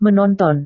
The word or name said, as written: menonton